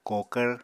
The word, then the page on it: kokvr: Apatani